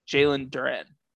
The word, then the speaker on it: Jalen Duren: My idea is like Detroit, even though they take Jalen Duren.